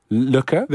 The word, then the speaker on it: Lykke: The little book of Lykke.